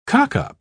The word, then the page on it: cockup: British English